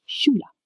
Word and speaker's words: Schule: SCH makes the sh sound, like in ship. So Schule, meaning school, is pronounced Schule. And Deutsch ends with TSCH, which makes a ch sound, as in church.